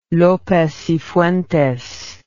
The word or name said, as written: Lopez y Fuentes